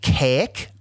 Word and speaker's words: Kazik: And they were from the planet Kaek, which is spelled K-A-Z-I-K. The Z is silent, which is just the most...